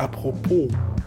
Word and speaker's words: apropos: Und das Wort apropos ist an sich falsch ausgesprochen. Es heißt ja eigentlich apropos. Das S wird nicht mitgeschrieben.